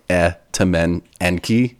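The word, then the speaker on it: Etemenanki: And then I was looking at pronunciations, and it was like Etamenenki, like there's spaces in between it.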